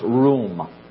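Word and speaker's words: ruwm: It comes from a root word in the Hebrew that's just simply pronounced room, but it's R-U-W-M.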